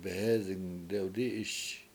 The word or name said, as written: behEzing dewdI7iS